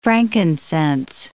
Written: frankincense

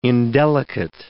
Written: indelicate

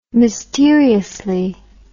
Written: mysteriously

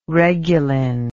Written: reguline

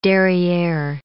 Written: derriere

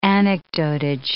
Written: anecdotage

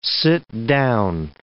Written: sit-down

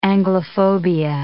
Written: Anglophobia

